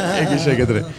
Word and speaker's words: Egészségedre: Egészségedre.